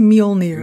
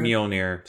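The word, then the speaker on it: Mjölnir: You called it Mohinder. – Yeah. – It's Mjolnir. – Mjolnir.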